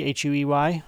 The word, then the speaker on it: Huey: It's spelled like Huey, Huey, but they pronounce it down there as Huey.